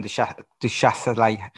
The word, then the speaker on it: du Châtelet: and that is the Roland du, Châtelet. How do I say it? Roland du du Châtelet? – Du yeah. Châtelet. Roland du Châtelet uh, era.